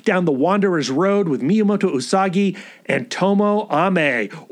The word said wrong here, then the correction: Tomoe Ame: Or is it Tomoe Ame? We had a correction. We will follow that correction. That's the proper pronunciation, apparently. Tomoe Ame.